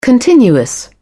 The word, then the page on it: continuous: British English